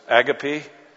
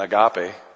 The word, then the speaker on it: agape: If you're from England, sometimes it's agape love. It's probably a better pronunciation than agape, but that's the love that God has.